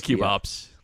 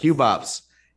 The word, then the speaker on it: QB ops: How do we pronounce? – We say cube ops. – Yeah. Cube ops.